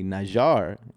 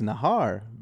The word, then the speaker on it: Najar: they would say Najar, which is the proper way to say it. And I'd say, no, it's Nahar because of me and my culture.